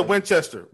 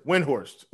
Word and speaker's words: Windhorst: Oh, I said Winchester. Windhorst. Oops.